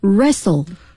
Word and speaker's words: wrestle: 接下来的两个词是和奥运会的比赛项目相关的，一个是摔跤 Wrestle. 大家注意这个词啊， WR 开头，那么往往有时呢这个 W 是不发音的，比如说这个吹哨子 Whistle 啊，像这里 Wrestle W 都是不发音的，在英语中被称作 unvoiced 啊，不发音，摔跤。